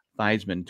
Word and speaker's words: Theismann: You know his actual name is Theismann, but he wanted to sound like Heisman, so he was in college. He changed it to Theismann to rhyme with Heisman.